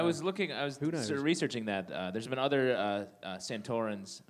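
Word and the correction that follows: Sontarans: am I saying that correctly? – Santarans.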